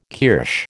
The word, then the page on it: kirsch: American English